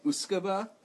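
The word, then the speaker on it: uisce beatha: So, I believe he does say it right, Uskaba. Mm-hmm. It's like, it looks like Uskabetha, and it's Gaelic for water of life.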